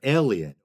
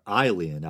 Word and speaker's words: Aelian: The following is from 3rd-century Roman writer and teacher Aelian, or Aelian, I believe, in classical Latin, the A-E being pronounced I, as in the Latin word caelum, meaning sky or heaven.